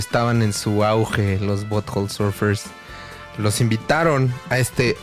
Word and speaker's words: Butthole: Butthole.